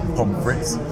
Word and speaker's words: pommes frites: Pommes frites. – Yeah. Pommes frites.